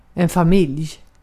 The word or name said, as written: ocak